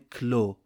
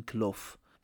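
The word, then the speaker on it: Klow: داخل داستان میبینیم هرژه اسم پایتخت سیلداویا رو گذاشته کلو شاید هم با تلفظ لهستانی باید بگیم کلوف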